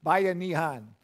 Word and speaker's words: Bayanihan: And so, Bayanihan. We had a young lady in our church that drove a neon. She used to say, buy a neon. And that's pretty close, but I wouldn't recommend you buy a neon.